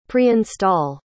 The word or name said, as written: preinstall